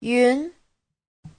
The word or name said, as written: yun2